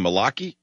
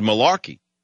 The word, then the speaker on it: Malachi: I don't even know it was a Jewish name, Malachi. I thought it was Malarkey, but it's Malachi.